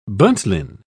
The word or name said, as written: buntline